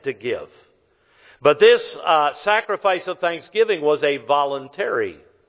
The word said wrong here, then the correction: voluntary: I put the emphasis on the wrong part of the word. It was a voluntary offering, this sacrifice was.